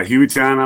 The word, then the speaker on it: Hueytown: You're an assistant principal at Hueytown Middle School in Hueytown, Alabama. Am I saying that right? – Yeah, Hueytown, Alabama.